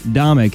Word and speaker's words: Domeck: We are joined with a special guest this show, Dr. Craig Domeck. He helped me out with this pronunciation. I appreciate that, Craig. You got it, man. Comic, it sounds like.